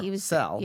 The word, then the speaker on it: Parcell: I think Notorious ROB last week said that's how you pronounce it. – Yeah, Parcell.